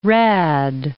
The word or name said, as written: rad